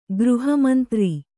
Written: ಗೃಹಮಂತ್ರಿ

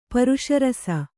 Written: ಪರುಷರಸ

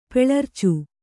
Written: ಪೆಳರ್ಚು

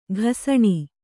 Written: ಘಸಣೆ

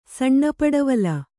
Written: ಸಣ್ಣಪಡವಲ

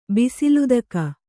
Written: ಬಿಸಿಲುದಕ

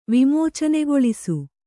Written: ವಿಮೋಚನೆಗೊಳಿಸು

